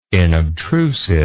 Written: inobtrusive

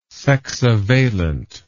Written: sexavalent

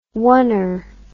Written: oner

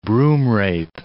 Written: broomrape

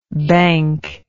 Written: banc

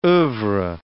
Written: oeuvre

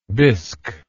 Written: bisk

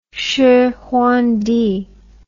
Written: shihuangdi

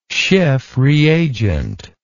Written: Schiff reagent

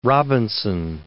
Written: Robinson

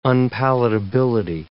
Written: unpalatability

